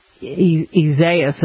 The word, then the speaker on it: Isaias: And, if you look at Isaias is actually how you pronounce it.